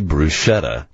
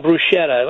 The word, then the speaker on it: bruschetta: Try our family-friendly bruschetta. Nope. Bruschetta. Nope. – Yeah, it's a supermarket product. There's somebody I can't remember who does it. That's, you know, frozen bruschetta.